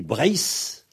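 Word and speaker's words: Breizh: Alors, donc, en tout cas, généralement, en KLT, enfin, Cornouailles, Léon, Trégor, Saint-Z, donc, la Bretagne se dit Brace. Alors, naturellement, le Z à la fin se prononce S, puisque en finale, les consonnes se prononcent toujours dur.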